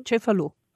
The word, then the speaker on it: Cefalù: Is that how you say it? Cefalù? – Cefalù.